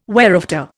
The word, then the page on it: whereafter: Indian